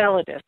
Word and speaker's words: Enceladus: And especially in Enceladus, am I saying that right? And whether they can support Enceladus. – Life. Enceladus, sorry about that. – Enceladus, yeah.